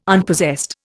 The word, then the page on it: Unpossessed: Indian